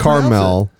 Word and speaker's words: Carmel: That's pronounced Carmel. – Carmel. – No, it's Carmel. – Carmel. – Carmel. – It doesn't have a second A, though, right? It's just C-A-R. – It's just Carmel, not caramel.